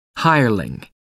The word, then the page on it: hireling: American English